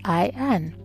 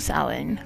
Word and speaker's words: Samhain: It is spelled funny because it is a Celtic word, Samhain.